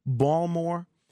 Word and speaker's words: Baltimore: It's just the way that he says Baltimore. It's so cool. So I know I was corrected yesterday that the way the Baltimoreans pronounce it is Balmore.